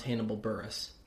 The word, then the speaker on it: Hannibal Buress: Also, it is pronounced Hannibal Buress.